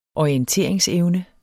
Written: orienteringsevne

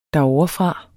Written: derovrefra

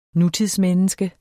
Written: nutidsmenneske

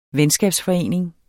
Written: venskabsforening

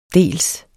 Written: dels

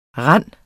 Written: rand

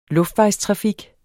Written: luftvejstrafik

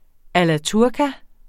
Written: alla turca

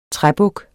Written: træbuk

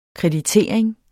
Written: kreditering

